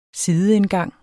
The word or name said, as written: sideindgang